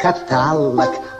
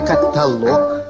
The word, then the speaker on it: каталог: Не говори каталог, а только каталог.